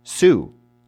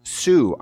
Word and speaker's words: Sioux: At that point, my teacher stopped me and said, that's pronounced Sue. And I said, I'm sorry, what? That word that you just read, it's Sue. I said, it's spelled S-I-O-U-X. Sioux. It's the Sioux Indians. No, that's Sue. No, Sue is S-U-E.